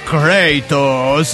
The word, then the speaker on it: Kratos: Kratos, se pronuncia Kratos.